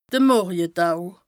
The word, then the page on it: de Morjetau: Bas Rhin; Strasbourg